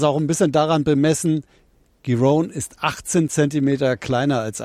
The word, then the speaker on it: Giron: Dritte Runde, weiß nicht, ob ich den Namen richtig ausgesprochen habe, aber das weiß Matthias Kammann sicherlich besser. Wie sieht's denn aus für den Hamburger? – Ja, er spricht sich tatsächlich Marcus Giron und Alexander Zverev tut sich bisher so leicht, wie man das erwartet hat.